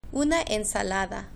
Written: una ensalada

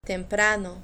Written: temprano

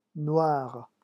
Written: noir

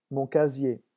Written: mon casier

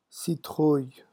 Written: citrouille

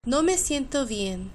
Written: no me siento bien

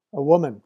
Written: a woman

